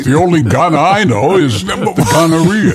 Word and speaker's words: Gaana: No, it's Ghana spelled G-A-A-N-A. – Spell it again. – Yeah, G-A-A-N-A.